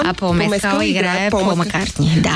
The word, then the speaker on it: Мескал: Но другото, което беше много симпатично, е, че Пол Мескал, който също... Пол Мескал, както е правил. правилното ударение. Пол Мескъл беше на премиерата на Urchin на Харис Дикинсън.